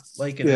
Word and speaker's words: Lankinen: Lankinen. How do you say his name? Yeah, Lankinen.